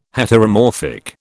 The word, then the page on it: Heteromorphic: British English